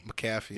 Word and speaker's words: McAfee: I don't want to get, you know, attacked like Pat McAfee whatever you say his name. – McAfee. Yeah, I had to learn hard, apparently. – McCaffrey? Is it an R? It's like McCaffrey without the R in it, right?